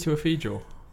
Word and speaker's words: Chiwetel Ejiofor: I thought it was Chiwetel Ejiofor. – There we go. Exactly.